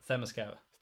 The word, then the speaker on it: Themyscira: Themyscira. – I was going to be like, Themyscira. – Themyscira, I loved...